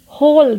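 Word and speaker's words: whole: यहाँ पे भी डब्ल्यू साइलेंट है होल W H O L E होल यानी पूरा होल डब्ल्यू इज साइलेंट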